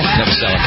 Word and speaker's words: Novoselic: Novoselic.